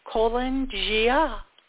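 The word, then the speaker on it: Calan Gaeaf: Then there is Kolan Gia, and that's spelled C-A-L-A-N, then G-A-E-A-F. So Kolan Gia is Welsh, celebrated the first day of winter, denoting this new beginning as Samhain, November 1st.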